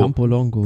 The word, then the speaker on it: Campolongo: Campagnolo, Camp, nee, Campolongo, Campolongo. – Campolongo. Campolongo. – Ja. Habe ich mal schon falsch ausgesprochen.